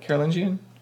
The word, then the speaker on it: Carolingian: Is that how you say it? Carolingian? – Yeah. – Good job.